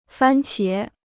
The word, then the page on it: 番茄: fān qié